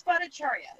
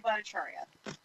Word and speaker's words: Bhattacharya: No, it's Bhattacharya. It's Lily Bhattacharya.